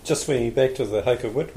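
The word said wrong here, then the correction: Hokowhitu: one, the Hokawhiti one, pronounce it more correctly.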